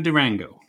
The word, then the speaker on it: Durango: Although she went by the name of Durango, a word which you'll find rhymes with mango.